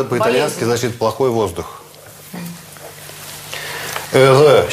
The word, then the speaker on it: heureux: Это произносится heureux, а это malheureux. Счастливый, несчастный. Вот можете посчитать, сколько здесь лишних букв.